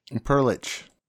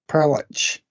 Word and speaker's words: Perlich: And how do you say his surname? Is it Max Perlich? – Perlich. – Perlich.